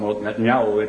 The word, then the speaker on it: Netanyahu: Netanyahu, as they call him, Netanyahu, they pronounce it over there, Netanyahu, Benjamin Netanyahu.